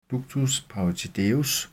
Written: ductus parotideus

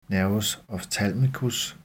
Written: n. ophthalmicus

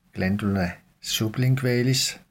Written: gl. sublingualis

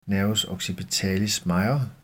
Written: n. occipitalis major

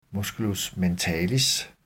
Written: m. mentalis

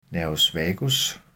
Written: n. vagus

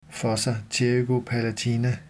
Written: fossa pterygopalatina

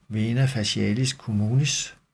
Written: v. facialis communis

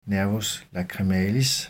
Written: n. lacrimalis

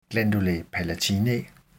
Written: gll. palatinae

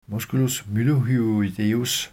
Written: m. mylohyoideus

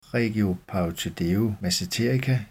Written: regio parotideomasseterica

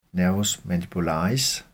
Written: n. mandibularis

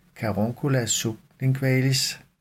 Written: caruncula sublingualis

